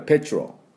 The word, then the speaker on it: petrol: P-E-T-R-O-L です。最後の O は強く発音しません。半母音、カッコ半母音でしょうね。ですからペチロ、ペチロ、 R 行ってすぐ L みたいな、はい、ペトロール、ペトロールとは言わないですね。